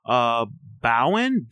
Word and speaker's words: Bowen: Bowen, B-O-W-E-N Yang.